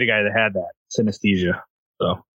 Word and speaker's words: synesthesia: S-Y-N-E-S-T-H-E. S-I-A. – Synesthesia.